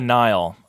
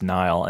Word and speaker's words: Nihil: It seemed seemed more like scavengers or whatever, you know, like they're called the Nile. You know, it's spelled N-I-H-I-L like nihilism, but pronounced Nile, and they were described as space Vikings.